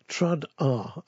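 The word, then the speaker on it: Trad Arr: Trad R, as in traditional arranged by. Trad R, a better spell it, is T-R-A-D-A-R-R-R. Yes, three R's.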